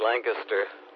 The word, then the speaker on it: Lancaster: I always think of the word canister. It's like Lancaster, canister. Same ending on it anyway.